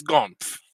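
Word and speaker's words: Gormf: It's Gomph." – "Gomph.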